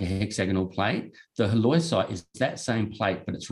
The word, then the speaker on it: halloysite: Halloysite, there we go. – Halloysite.